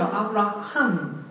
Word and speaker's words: Avraham: 原文是 Avram 就是那个亚伯兰，那亚伯拉罕叫 Avraham 啊，就多了一个 H 这个发音。